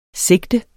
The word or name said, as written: SIGTENS